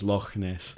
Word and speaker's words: Loch Ness: When JQ says Loch Ness, he really means Loch Ness, which is how the word is actually pronounced.